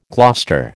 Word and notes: Gloucester: American English

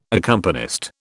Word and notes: accompanist: British English

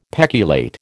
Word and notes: peculate: American English

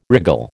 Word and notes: wriggle: American English

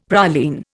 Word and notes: praline: Indian

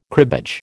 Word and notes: cribbage: American English

